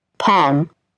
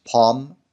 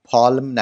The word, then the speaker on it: palm: و مورد بعدی هم نمبر 6 پام به معنای کف دست هست و ال تلفظ نمیشه در قرائت این کلمه. پام. پالم نگید لطفاً. پام.